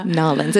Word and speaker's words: New Orleans: And Nolens. It's pronounced Nolens.